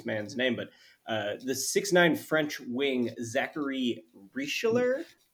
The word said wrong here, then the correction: Risacher: Yeah. Zachary Risacher. – Risacher.